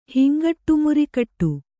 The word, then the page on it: ಹಿಂಗಟ್ಟುಮುರಿಕಟ್ಟು: kannada